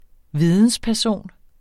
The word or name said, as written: vidensperson